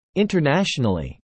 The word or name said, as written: internationally